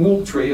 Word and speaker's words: Moultrie: This is Colonel William Moultrie, as they pronounce it in South Carolina, and he has a chapter in his memoir about the invention of this flag.